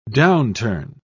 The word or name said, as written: downturn